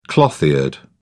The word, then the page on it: cloth eared: British English